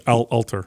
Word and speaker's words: Alter: The whatchamacallit book that I mentioned 1,000 times on this podcast and how I was Adam Atler. – No, yeah. It's Alter. – Yeah, Alter. I said it wrong.